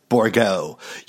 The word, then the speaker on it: Borgholt: And just an FYI for next time, it's pronounced Borgo. You know us French people always adding in letters to our name that we never even fucking use.